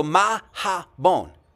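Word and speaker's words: Mahabone: So Ma-ha-bon. Three syllables, by the way.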